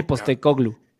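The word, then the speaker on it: Postecoglou: Postoglu, no sé cómo se pronuncia. Ajá. Postecoglu. – Postecoglu, cabrón, lo está haciendo magnífico.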